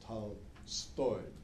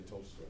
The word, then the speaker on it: Tolstoy: By the way, the accent is on the second syllable. It's Tolstoy in Russian. But you can say Tolstoy if you want to in English, but not in Russian.